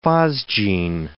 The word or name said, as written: phosgene